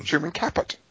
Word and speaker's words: Truman Capote: Truman Capote.